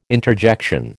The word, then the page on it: interjection: American English